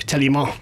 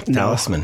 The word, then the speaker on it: Talisman: Am I pronouncing that correctly? – That is correct. – Okay, because yes. It could be like some French, like Talisman. – No, Talisman. No.